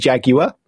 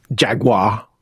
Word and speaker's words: Jaguar: Between us is this month I've done Tea Time with Tim on the Atari Jaguar, which is three syllables when you say it. – Jaguar. – There you go. Two syllables. – Jaguar. – American. – Jaguar. It's Jaguar.